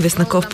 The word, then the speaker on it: Веснаков: Да, дебютен филм на Павел Веснаков, или Веснаков по-точно е ударението.